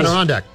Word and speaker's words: Adirondack: Adirondack.